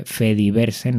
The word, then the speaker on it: Fediverse: Eh, FreeSol 2022, que es el 23 de abril y que te digo ya, tanto Fediverse TV, Fediverse, no Fediverso, que yo lo dije mal en el anterior episodio, en Fediverse TV van a poner lo que es algún FreeSol que sería online en este sentido.